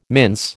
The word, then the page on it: mince: American English